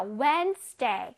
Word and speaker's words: Wednesday: 자, 저기서 D는 소리를 내지 않습니다. 오케이? 그냥 무늬만 D가 있는 거예요. 자, Wednesday. 근데 끄 소리는 살짝 딱. 봐봐. Wednesday. Wednesday.